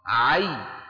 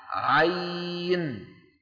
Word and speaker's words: عين: أما المجموعة الرابعة ففيها حرف واحد لا غير، وهو حرف عين ينطق على ثلاثة أحرف أوسطها حرف لين. شو يعني حرف لين؟ يعني ياء ساكنة مفتوح ما قبلها، عين عين، ويمد بمقدار أربع أو ست حركات لحفص من طريق الشاطبية، هي لكل القراء السبعة من طريق الشاطبية يمد أربع أو ستة، ويلحق بمد اللين.